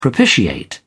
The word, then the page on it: propitiate: British English